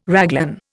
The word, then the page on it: raglan: Indian